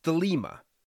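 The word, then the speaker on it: thelema (θέλημα): The word for will used here in Greek is pronounced thelema,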